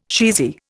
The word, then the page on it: cheesy: Indian